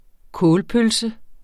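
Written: KÅLPØLSER

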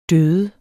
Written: døde

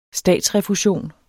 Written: statsrefusion

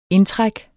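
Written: indtræk

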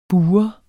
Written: bur

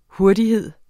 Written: hurtighed

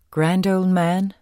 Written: men

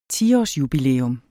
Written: 10-årsjubilæum

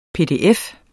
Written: PDF

